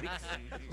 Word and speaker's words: disc: Compact Discs. No, Dix no, D-I-X no es. Es D-I-S-C. Claro, disc.